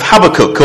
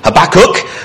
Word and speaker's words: Habakkuk: Really excited today to start studying the little book of Habakkuk, or if for our North American friends, Habakkuk, but Habakkuk is how it's pronounced in this country, and we're obviously right because we're closer to Israel than America is, so we're obviously right.